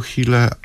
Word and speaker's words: Huelle: w jaki sposób pisze się nazwisko Chile, a pisze się zupełnie inaczej niż się wymawia, bo mówi się Paweł Chile, a napisane jest Paweł Huelle.